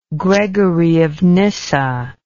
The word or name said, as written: Gregory of Nyssa